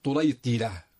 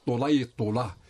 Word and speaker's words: طليطلة: تلفظ طليطلة بكسر الطاء الثانية وتلفظ أيضا طليطلة